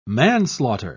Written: manslaughter

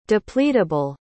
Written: depleteable

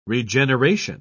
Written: regeneration

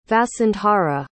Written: vasundhara

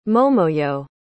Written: momoyo

